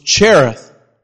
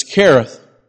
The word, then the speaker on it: Cherith: I thought the pronunciation was Cherith, but it's Cherith.